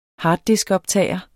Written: harddiskoptager

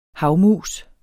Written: havmus

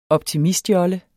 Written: optimistjolle

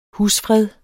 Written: husfred

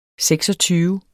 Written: seksogtyve

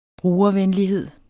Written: brugervenlighed

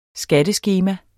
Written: skatteskema

